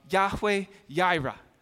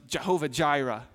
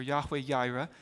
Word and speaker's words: Yahweh Yireh: You hear the name in that chapter of Yahweh Yireh, which sometimes gets pronounced Jehovah Jireh in our culture, Yahweh Yireh.